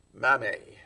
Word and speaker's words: mamme: It's spelled M-A-M-M-E. Mame. Mother.